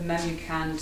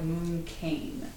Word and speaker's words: Memucan: You would pronounce it instead of Memucan, sort of a vowel gets shortened here and lengthened there, and it's sort of more like Mooncane instead of Memucan.